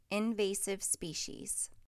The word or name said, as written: Invasive species